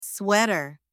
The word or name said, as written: sweater